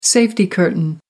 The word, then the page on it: safety curtain: American English